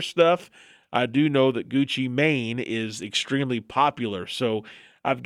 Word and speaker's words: Gucci Mane: Rapper Gucci Mane. Did I say that right? – Correct, yeah. – Gucci Mane. Not man, Gucci Mane.